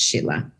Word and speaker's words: Scilla: My name is Sheila Andreen, and it's spelled like Skilla, S-C-I-L-L-A, but it's pronounced Sheila.